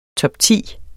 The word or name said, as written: Topti